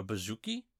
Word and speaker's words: bouzouki: So, like, for example, there's an instrument called a bouzouki, I believe is how it's originally pronounced.